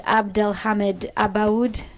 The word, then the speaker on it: Abdelhamid Abaoud: I can't really pronounce it, Abdelhamid Abaoud. – That was good. That was it.